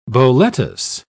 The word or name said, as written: boletus